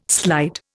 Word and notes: slide: Indian

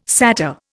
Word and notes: satyr: Indian

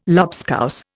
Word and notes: lobscouse: Indian

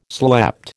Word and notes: slept: American English

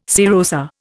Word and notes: serosa: Indian

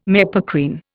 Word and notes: mepacrine: Indian

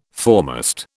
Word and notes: foremast: British English

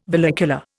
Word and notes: vallecula: Indian

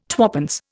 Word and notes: twopence: Indian

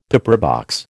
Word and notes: pepperbox: American English

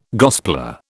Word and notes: gospeller: British English

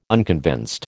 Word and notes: unconvinced: American English

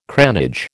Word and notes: cranage: American English

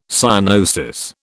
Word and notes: cyanosis: British English

